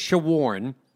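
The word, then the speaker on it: Shawaryn: But he says it's Shawarn, like I'm trying to shorn you about the impending Texas Rangers series.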